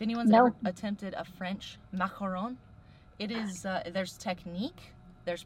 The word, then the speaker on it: macaron: So you started Sweet Mac Shop, and it's macarons. Did I say it properly? – It's kind of like a real phlegmy. – Macaron. I got a little more French. Macaron. Okay. Macaron. Yeah. You got it.